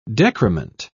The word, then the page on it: decrement: British English